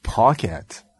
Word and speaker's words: Paquette: We learned the correct pronunciation of Rene Paquette. – It's Paquette. – I think that's how she said it. – Paquette. – Paquette. Huh. Because Rene compliments Owens on properly enunciating her name.